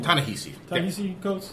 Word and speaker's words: Ta-Nehisi Coates: Ta-Nehisi Coates.